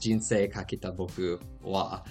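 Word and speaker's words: Jinsei Kakete Boku wa (人生×僕=): So 1OK Rock actually rose to fame in 2012 with the track The Beginning from their sixth album, Jinsei Kakete Boku wa, which it's spelled out very differently. It's Jinsei X Boku equals, but that is pronounced Jinsei Kakete Boku wa, which boosted them to international fame.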